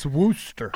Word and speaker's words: Worcester: But no, that's Worcester.